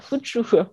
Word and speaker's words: Fuchu: Mais l'hippodrome de Tokyo en lui-même, il est à Fuchu, si je le prononce bien. Fuchu. Fuchu, voilà, Fuchu.